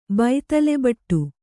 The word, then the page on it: ಬೈತಲೆಬೊಟ್ಟು: baitale boṭṭu